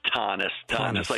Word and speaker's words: Thoennes: It's Thoennes, like Thomas with an N, Thoennes. You said Thoennes. It right the first time, actually.